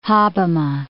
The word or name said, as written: Hobbema